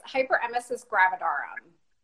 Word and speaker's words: hyperemesis gravidarum: It's hyperemesis gravidarum.